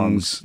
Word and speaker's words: Hmong: I think the H is silent. I think it's called Hmongs. – Yeah, you're right. The H-M-O-U-N-G, is it? – Yeah.